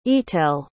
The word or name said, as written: etel